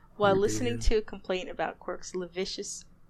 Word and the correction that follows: lascivious: Wait, how do you say that word? – I think it's lavicious. I think you have it. – Oh, because that's not how it's spelled. Because it's L-A-S-C-I-V. Anyway. Lavicious behavior. Lascivious behavior.